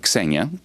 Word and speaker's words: Xenia: It's in Greek, it's Xenia. In English, you would say Xenia, and it's the goddess of, in ancient Greek, of kindness to foreigners.